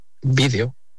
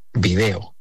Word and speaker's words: vídeo: Y vídeo es otra palabra que utilizo mucho en mis clases, siempre con mis clases a cuestas, para explicar la diferencia entre palabras esdrújulas, vídeo, como lo pronunciamos en España, y palabras llanas, video, como lo pronuncian en Latinoamérica.